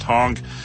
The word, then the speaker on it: HAAUG: Taking place a little bit before the happenings at Hal PC, the Houston Area Apple Users Group, aka HAAUG, pronounced Hong,